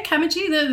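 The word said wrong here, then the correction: camogie: The female hurling. – Kamogi. – There we are.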